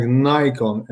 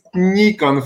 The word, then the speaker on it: Nikon: Because my friends from the United States pronounce the name Nikon like Nikon, and something like Nikon does not exist. And this is my favorite subject of the conversation. This is Nikon, for the God's sake. The pronunciation Nikon. Only Americans call us Nikon, which is absolutely wrong.